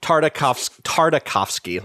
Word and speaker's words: Tartakovsky: Gendy Tarkovsky, is that right? – Yeah. – Gendy. Yeah. Not Tarkovsky. – I think we know. – Tartakovsky. Let me get his name right.